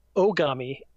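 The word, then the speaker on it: Ogami (狼): Something I have noticed is that in the first half of the 20th century, white people apparently couldn't pronounce Japanese words that began with O. So they always pronounced it as with a Y in front of it instead. And Ogami or Ogami is really a closer pronunciation, just means wolf in Japanese.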